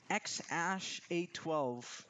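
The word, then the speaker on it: X Æ A-12: It's they say it's pronounced X Ash A twelve.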